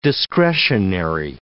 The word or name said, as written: discretionary